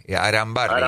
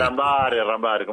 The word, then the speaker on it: Arambarri: Con una R, Arambarri. – Arambarri, como le gusta a usted. – No, es como se dice. – No estamos hablando de... – Es un apellido, tengo que ir pronunciarlo bien.